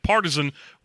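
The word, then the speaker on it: Partizan: I made sure that Partisan, which is spelled with a Z where the S would go, just so everybody knows, is pronounced the same as the word Partisan, whether you are describing the spear or the person.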